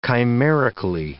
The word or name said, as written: chimerically